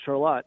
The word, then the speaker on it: Charlotte: It's spelled Charlotte, but it's Charlotte.